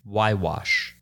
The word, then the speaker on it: Huayhuash: No, I feel like you don't pronounce the Y. Huayhuash. Of the Peruvian Andes. – You did pronounce the Y. – I meant the H. Y-wash. Like you don't pronounce the H or something? – That makes sense. – Sula Grande Y-wash. Yeah. The Cordillera Y-wash.